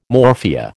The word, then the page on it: morphia: American English